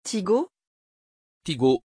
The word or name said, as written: Tigo